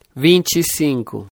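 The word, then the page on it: 25: Brazilian Portuguese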